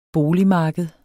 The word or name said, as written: boligmarked